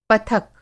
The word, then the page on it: पथक: پتھک